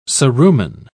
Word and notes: cerumen: British English

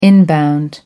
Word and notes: inbound: American English

